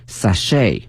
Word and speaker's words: sachet: 首先呢，我们看一下 ch，它呢是发成 sh 音哈，不是 ch 音哈，同学们注意。另外一个呢，这个 t 是不发音的。 T 是不发音的，所以呢我们要注意这个词。另外呢在美式发音当中它的重音放到后面 sachet、啊、 sachets。